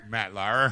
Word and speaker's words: Matt Lauer: Matt Lauer.